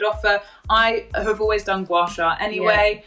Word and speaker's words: gua sha: And then the brand Hyu Method got in contact with me and they were like, oh, would you like to offer our gua sha products and the oils?